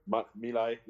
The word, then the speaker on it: My Lai: My Lai, yeah.